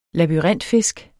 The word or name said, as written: LABYRINTFISK